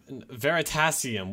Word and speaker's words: Veritasium: Veritasium. What the heck is wrong with you? – Wait, what just happened? What just happened? – Wait, Veritasium. – He pronounced it Veritasium and it's pronounced Veritasium.